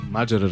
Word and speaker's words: Маджера: Абе, верно ли Маджера е правилното ударение? – Разбира се. – Маджера, разбира се.